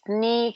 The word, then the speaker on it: knee: Когда K стоит перед согласным в начале слова, то она не читается. В таких словах, как know, knowledge, knock, knife, Knight, как рыцарь, and knee, которая коленка.